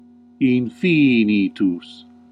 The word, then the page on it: unlimited: [.ˈlɪ.mə.təd]